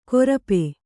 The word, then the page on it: ಕೊರಪೆ: korape